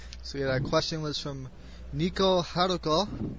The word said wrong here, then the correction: Neko Haruko: I'd like to thank Neko Haruko. – Neko Haruko. – Yeah.